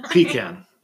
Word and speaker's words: pecan: Neither. Pecan. Accent on the first syllable.